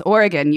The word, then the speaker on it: Oregon: Yes, and that's Oregon. You actually said it right.